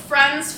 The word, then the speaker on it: Phran: Guys, go head to France Farm Organics and it's spelled P H R A N.